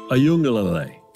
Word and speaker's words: Uiagalelei: And their quarterback, and I'm going to let you pronounce the name so I can pretend like I know how to pronounce the name, but their quarterback, how is he looking to you? – DJ Uiagalelei. That's how we say it in Charlotte.